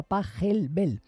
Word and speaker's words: Pachelbel: Ya sabéis, se escribe Pachelbel, pero se pronuncia Pachelbel. Bueno, los alemanes son así.